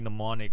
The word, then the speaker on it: mnemonic: He has an even better line, actually, in Johnny Mnemonic, where he's like, oh, man, I gotta bring this up because... – It's spelled with an M.